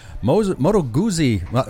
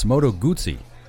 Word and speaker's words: Moto Guzzi: Moto Guzzi. I'm saying it wrong. It's Moto Guzzi enthusiast, Nick Adams.